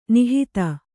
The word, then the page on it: ನಿಹಿತ: nihita